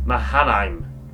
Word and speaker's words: Mahanaim: And he called the name of that place Mahanaim, two hosts or camps.